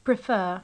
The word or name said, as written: prefer